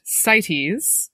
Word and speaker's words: CITES: And so I was wondering if you could talk a little bit about, the acronym is CITES. It is pronounced CITES.